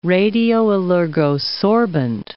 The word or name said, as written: radioallergosorbent